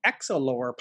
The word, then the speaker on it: Exolore: Uh, you have a podcast called the Exolore Podcast. Am I pronouncing that right? Mm-hmm okay, great.